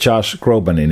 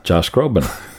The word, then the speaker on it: Josh Groban: Sa mõtled Josh Groban'i nime? – Täpselt, ütle nüüd tema nimi. – Josh Groban. – Täpselt, nii ta ongi.